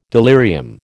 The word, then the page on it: delirium: American English